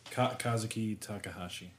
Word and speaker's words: Kazuki Takahashi: Creator, and I'm going to butcher his name. – Kazuki Takahashi. – He died at that's actually correct.